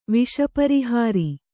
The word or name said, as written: ವಿಷಪರಿಹಾರಿ